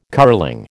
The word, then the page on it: curling: American English